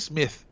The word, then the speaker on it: Smith: Yo creo que es Smith, porque su apellido es Smith. – Sí, pero hay una diferencia entre cómo se pronuncia.